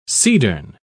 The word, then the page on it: cedarn: British English